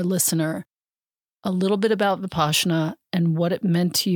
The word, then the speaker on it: Vipassana: Vipassana is the proper way to say it, in 2012.